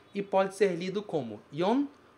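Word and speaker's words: yon (四): O primeiro caractere significa 4, e aqui ele é pronunciado yon.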